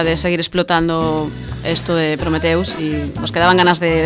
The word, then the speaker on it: Prometheus: Os metéis conmigo por Prometheus, pero se dice así en inglés. – Sí, sí, sí, lo sé. – Prometheus.